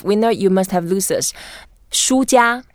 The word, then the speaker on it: 输家: Yeah, so 输家. – 输家. – Yeah, 输 is the first tone, and that just means you lost something. And it's also the same pronunciation as books in Chinese.